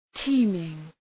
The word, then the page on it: teeming: ‘ti:mıŋ